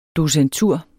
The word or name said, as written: docentur